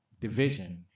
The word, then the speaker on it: DVSN: They call themselves Division, but it's spelled DVSN and it's pronounced Division.